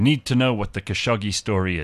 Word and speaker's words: Khashoggi: Yeah, it says on YouTube it's Khashoggi.